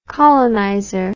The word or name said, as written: colonizer